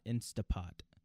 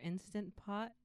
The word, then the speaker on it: Instant Pot: I don't know if there's two different brands, but our brand is Instant Pot, not Instapot. – I think all of the brands are Instant Pot, but we just say it wrong, like we say everything else wrong.